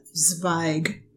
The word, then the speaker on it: Zweig: And one of our very kind listeners posted a proper pronunciation of the Zweig name of the sweater. So I'm going to try Zweig this week. And I think that's I think that's the proper pronunciation now.